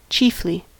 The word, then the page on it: chiefly: American English